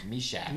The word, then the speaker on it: Meshach: Meshach.